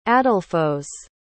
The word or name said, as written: adelphos